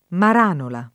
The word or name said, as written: Maranola